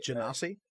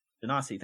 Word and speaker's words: Genasi: A Genasi. – Genasi, thank you. – Genasi. – A Genasi. – Yes. – With a sword. But what is particularly interesting for Sid is that this Genasi did not draw their sword throughout the entire fight, yet were easily able to handle this thug.